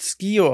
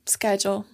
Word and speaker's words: Skyjo: Da gibt es keine definitive Aussprache, glaube ich. – Na, dann Skyjo, Leute.